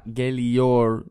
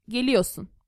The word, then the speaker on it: geliyorsun: And so not geliyor. – No, no, no. We don't pronounce R. Geliyorsun.